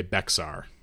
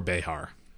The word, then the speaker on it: Bexar: And this is across from the city of San Antonio proper, which at that time was called the Villa de Bexar, or Behar, depending on your tongue of pronunciation.